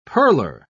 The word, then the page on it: pearler: British English